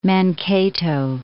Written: Mankato

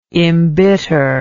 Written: imbitter

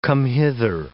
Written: come-hither

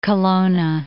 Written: Kelowna

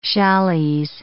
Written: challises

